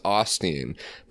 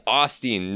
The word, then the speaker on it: Osteen: When technically the name's pronounced Osteen, but somebody made a stink about that on Twitter. It was like, it's Osteen, not Osteen. And someone was like, no, it's Osteen now.